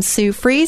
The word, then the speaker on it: Sue Fries: Sue Free, spelled like fries, one word, .com.